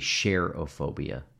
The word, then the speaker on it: cherophobia: It must be cherophobia. Cherophobia. – Spell it. – C-H-E-R-O phobia. – Chero. – It's not the fear of Cher. It comes from the Greek word chero, which means to rejoice.